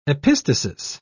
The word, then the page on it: epistasis: British English